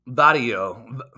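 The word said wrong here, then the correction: Vattiato: That's not how you say his name. Let me rewind this and hear it. Vadiato. Yes, that's what it is. Nicholas Vadiato is how you pronounce the name.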